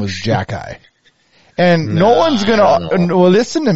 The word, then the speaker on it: Xhekaj: And his last name is spelled XHEKAJ. Mm-hmm. Which I've looked at it a million times, and in no way do I see how it spells out Jack-Eye.